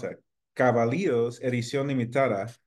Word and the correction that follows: Cabildos: Cabildos. – Cabildos. – You added an extra syllable. – I want to say it's like pronouncing it like a double O. Cabildos.